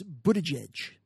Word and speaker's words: Buttigieg: Well, I don't know if I'm saying it properly, but I think I read it phonetically that it's Buttigieg.